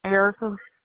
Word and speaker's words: Erica: Erica. – Spell it for me, please. – E-R-I-C-A.